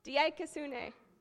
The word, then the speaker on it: dikaiosune: dikaiosune. I butchered that. It's been a long time. Dikaiosune.